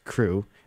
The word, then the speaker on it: CHRU: The Carolina Harm Reduction Union, also known as CHRU, pronounced CREW, is a student-led program that provides life-saving harm reduction resources to the public.